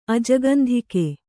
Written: ಅಜಗಂಧಿಕೆ

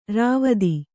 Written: ರಾವ್ತ

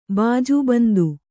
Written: ಬಾಜುಬಂದು

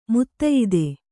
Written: ಮುತ್ತಯಿದೆ

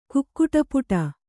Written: ಕುಕ್ಕುಟಪುಟ

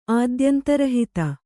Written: ಆದ್ಯಂತರಹಿತ

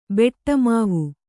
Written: ಬೆಟ್ಟಮಾವು

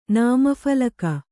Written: ನಾಮಫಲಕ

